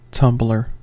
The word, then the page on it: tumbler: American English